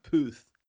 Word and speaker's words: Puth: Now, how do you say yes. that? Puth. – P U T H, Puth. Puth.